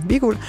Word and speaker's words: hvidguld: Altså, en eller anden form for hvidguld. Selvfølgelig udtalt som viguld.